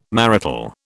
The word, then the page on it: marital: British English